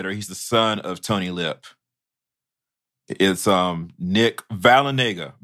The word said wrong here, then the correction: Vallelonga: Vallelonega. Vallelonga. Oh, God. Okay. I knew I was going to tank it. Vallelonga.